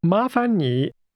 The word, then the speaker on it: 麻烦你: This expression for please is pronounced with two rising tones, followed by a falling, rising tone. Say again, please. – 麻烦你。